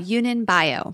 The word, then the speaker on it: Yunnan Baiyao: the Yanyan Bio. Uh, Yunnan Baiyao.